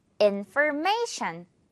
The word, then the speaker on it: information: 강세가 in에 하나 그리고 may에 하나 있거든요. may를 조금 더 강하게 한다고 생각하면 돼요. 그리고 마지막은 shan 발음으로 끝내주는 거죠. Information.